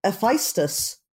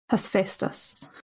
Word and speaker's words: Hephaestus: Wait, Hephaestus? – Is that... – Or am I saying it now differently? Am I saying... I thought it was Hephaestus always. – I always heard it as Hephaestus. – Tomato, tomato, indeed. – I'm American, so, you know, we all pronounce things differently. – I'm a non-native speaker. To me, it's Epheso. So...